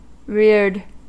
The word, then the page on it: reared: American English